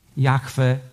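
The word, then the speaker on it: Jahwe: My wiemy, że to imię po hebrajsku brzmi Jachwę, Jehowa. Różnie, różnie, różnie to jest, jest, jest odczytywane.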